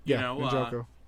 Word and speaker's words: Njoku: Njoku. – Njoku.